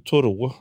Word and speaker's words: Thoreau: Jag hade ju som Thoreau. Jag försöker vänja mig att han var amerikan och inte fransman, så det är uttalet där.